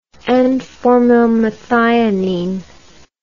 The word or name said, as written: N-formylmethionine